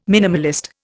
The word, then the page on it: minimalist: Indian